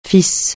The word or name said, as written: FIS